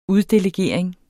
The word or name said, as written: uddelegering